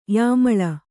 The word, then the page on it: ಯಮಲಿ: kannada